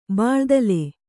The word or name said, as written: ಬೋಳುದಲೆ